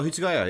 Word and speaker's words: Hitsugaya: And yeah, and then there's this character called I don't know how to say it in English, Hitsugaya. – Oh, Hitsugaya, yeah, the white-haired little kid, yeah.